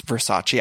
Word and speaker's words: Versace: And I just want to let everyone know, of course, I know that it's Versace. I was using the Showgirls pronunciation.